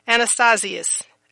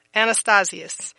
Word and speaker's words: anastasis: Again, Strong's 386, and it's Anastasius. Anastasius, and that is spelled A-N-A-S-T-A-S-I-S. Again, A-N-A-S-T-A-S-I-S.